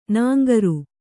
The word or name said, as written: ನಾಂಗರು